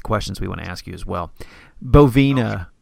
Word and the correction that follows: Bovanova: am I pronouncing that right? – Bovanova, kind of like Bossa Nova. – But that's right. – Style. – Sorry, man. It's right in front of me. Bovanova.